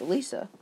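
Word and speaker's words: Elesa: Do you have anything on Elsia, dude? Mm. Elisa? I said it wrong. It's Elisa.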